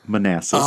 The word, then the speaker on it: Manassas: So I just like to point out that this happened in Manasses, Virginia. – It's pronounced Manassas. – Oh, Manassas. But yes, it is Manassas.